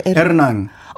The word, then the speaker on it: 에르난: 에르난. – 아, 진짜 그렇게 하는 거구나. – 예, 예. 네. 르르 발음을 굉장히 심하게 해요. 그 스페인 말 할 때.